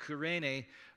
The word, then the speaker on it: Cyrene: Cyrene, in Greek, is pronounced Kyrene, was a city on the Mediterranean coast of Africa.